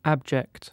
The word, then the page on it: abject: British English